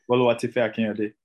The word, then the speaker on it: Boluwatife Akinyode: Boluwatife Akinyode.